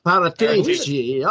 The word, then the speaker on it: Paratici: Yeah, and yes, we haven't been good at this in the past, but that's why we bought Patricio in. I don't know that you were pronouncing that differently. I'm probably Paratici. – Incorrect. Paratici.